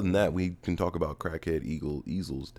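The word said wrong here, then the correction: Ezal: death. – Crackhead Ezel. – Ezel. – Ezel. – Always. When I was a kid, I was. – You never watched the fucking movie? – I've watched the movie. – They say his name like 20 times. It's Ezel. – I didn't give a fuck as a kid. – And I'm telling you now, it's Ezel.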